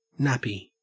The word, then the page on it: nappy: Australian English